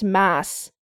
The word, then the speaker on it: Mads: His first name, M-A-D-S, is apparently pronounced Mass.